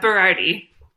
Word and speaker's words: Berardi: Dr. John Berardi. I have pronounced that right, haven't I? – Yeah, Berardi.